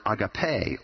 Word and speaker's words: agape: There is this word agape, or agape, as some pronounce it, that is the New Testament definition of love.